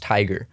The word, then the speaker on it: Tyga: It's pronounced Tiger. – No, it's not.